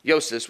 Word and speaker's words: Joses: His real name was Joses would probably be how to pronounce it. They would pronounce the J with a Y sound.